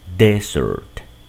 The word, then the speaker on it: desert: Acá, en cambio, desierto lleva solo una S y el acento recae en la primera sílaba. Desert, desert, desert. En ejemplos queda así.